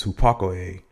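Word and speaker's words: hupakoe (ὑπακοή): And the Greek translation for obedience is hupakoe, and it's spelled hupakoe with the emphasis on the E.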